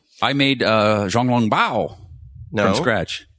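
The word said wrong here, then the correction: xiaolongbao: How do you say that? Zhao Long Bao? – No. – Zhao? – Xiao. – Xiao Long Bao.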